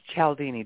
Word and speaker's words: Cialdini: His name is Cialdini, Dr. Robert Cialdini, and he is the godfather of influence.